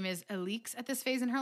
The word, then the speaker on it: Alix: So one thing that we heard from our friend Levi, who is listening to the audiobook, is that we've been saying Alex's name wrong. – Yeah. Levi informed us that the audiobook version says her name, Alix.